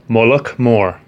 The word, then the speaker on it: Mullaghmore: Mullagbar. – And you're from County Sligo? – It's pronounced Mullagh Mór. Yeah. Get it right.